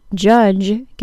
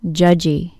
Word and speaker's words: judge: The part that can be a little confusing is that there is not actually a D sound in the D-G-E spelling. It's only a J sound. The second problem I hear with the J sound is adding a vowel sound to the end of a word that ends in a J sound. So the word judge gets pronounced judgy.